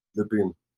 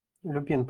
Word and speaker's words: Люпин: А, это Люпен. Боже, скажите мне, пожалуйста, как правильно произносить? Я постоянно... Люпен, Лю... Люпен, Люпин. Люпин – это что-то с Гарри Поттера. – Люпин. – Люпин, правильно. Люпин, Люпин.